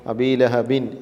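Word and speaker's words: അബീലഹബ് (أبي لهب): ഇപ്പോൾ അബീലഹബിൻ എന്നത് അബീലഹബ് എന്ന് ബാഇന് സുക്കൂൻ ചെയ്ത് പറയുന്നു.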